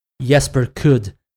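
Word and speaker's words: Jesper Kyd: I think most of the works by according to Assassin's Creed Valhalla, this is the proper pronunciation, Jesper Kudd.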